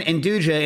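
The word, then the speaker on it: 'nduja: Stas, am I pronouncing that right, by the way? – What? – Is my Induja? – Yeah. – Induja? – That's how you pronounce it? – Yeah. – Which? Ja or ya? Ya, ja. Ya, ja. Ya, ja. – The way Kat said it.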